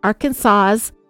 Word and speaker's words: Arkansas: It's not Arkansas, it's not Arkansas, it must be pronounced Arkansas.